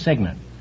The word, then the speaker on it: cygnet: It's spelled C-Y-G-N-E-T, but it's pronounced Signet.